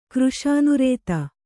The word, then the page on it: ಕೃಷಿನಿರತ: kannada